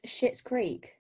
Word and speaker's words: Schitt's Creek: Amelia absolutely loves Schitt's Creek. We're not swearing. It's spelled S-C-H-I-T-T-S.